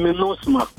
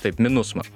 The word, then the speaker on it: MINUSMA: Atsiprašau, operacija vadinasi MINUSMA. – MINUSMA, taip? – Taip, MINUSMA. Taip, galbūt neteisingai ištariau. MINUSMA vadinasi ši operacija.